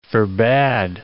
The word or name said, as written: forbad